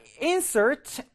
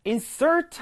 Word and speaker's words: insert: 자, insert. 발음이 두 가지야. 명사일 때는 insert, 동사일 때는 insert 가시면 되는데요. 자, 내가 널 insert 하려고 동사죠.